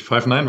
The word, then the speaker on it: Five9: Ja, ist richtig. Five Nine wird ausgesprochen.